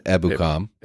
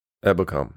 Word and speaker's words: Ebukam: That is the correct pronunciation. Ebukam. Ebukam. – Ebukam. – You got it. Nailed it.